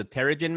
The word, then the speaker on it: Terrigen: And if you're exposed to something called the, I hope I'm saying this correctly, Terrigen Mist. – Terrigen. – Yeah, Terrigen Mist.